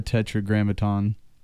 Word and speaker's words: tetragrammaton: And the reason this could be culturally controversial is the reason we have the tetragrammaton. Did I say that right? – Mm-hmm.